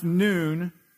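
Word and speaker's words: noon (نون): And it's pronounced noon, and it stands for Nazarene or Nasrani.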